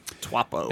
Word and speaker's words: Taupo: Taupo? – I think he nailed it with that. Taupo.